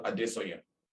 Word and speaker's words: Adesanya: I'm aware Israel Adesanya.